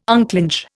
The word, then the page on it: unclinch: Indian